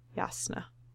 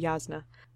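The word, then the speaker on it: Jasnah: Yasna. Oh yeah, it's an S sound, and I still say Yasna, which it's supposed to be an S.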